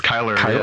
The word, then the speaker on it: Chyler: Chyler Lee or Leigh, I don't remember how her name is pronounced. – Yeah, it's Kyler. – Oh, it's Kyler.